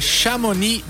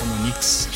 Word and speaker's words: Chamonix: E o Cume FM de hoje te leva diretamente para Chamonix, na França. Falei certo? – Sim. Mas bem que eu ouvi gente falando de forma diferente, Chamonix. – Porque tem um X ali, um X mudo, né? – Pois é.